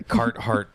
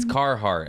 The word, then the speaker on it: Carhartt: My poor producer is going to have to wear a Carhartt pant. I even say that wrong. It's Carhartt. Actually, Stephen Fury corrected me on that one.